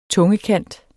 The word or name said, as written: tungekant